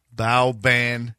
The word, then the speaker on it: Boban: Yeah, it's pronounced Boban Marjanovic. – It is not. – That's what it says. Bowban. Boban Marjanovic.